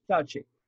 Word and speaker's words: Fauci: Dr. is it Anthony Fauci, is that how it's pronounced? – Yeah, Fauci.